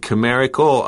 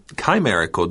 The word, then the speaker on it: chimerical: Joanna said chimerical. I said chimerical during the reading. Guess what? Both pronunciations are okay.